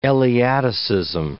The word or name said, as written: Eleaticism